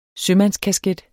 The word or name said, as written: sømandskasket